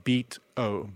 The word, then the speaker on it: Beato: I think I'm saying it right. Beato, like Beato, B-E-A-T-O.